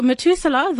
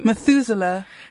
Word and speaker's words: Methuselah: Excuse me for the bit of a brain scramble. So Methuselah, the oldest man in the Bible, died at what age? How do you say his name? – Methuselah. – See, both seem probable to me.